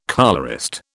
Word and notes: colourist: British English

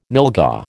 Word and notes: nylghau: American English

